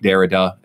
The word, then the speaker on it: Derrida: people like Jacques Derrida, if I'm saying it right, it's like D-E-R-R-I-D-A. And that was a Derrida. I think it's Derrida. Right, I just say it how it looks to me.